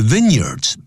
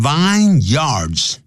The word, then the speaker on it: vineyard: The vineyards, 不要念成 vineyards, 记住它合在一起要念成 vineyards, vineyard, 或者就念成 year, 连在一起 vineyard, the vineyards of the Cape, 这个开普省的这些葡萄园啊 are thought to be the best, 那么是被认为是最棒的啊。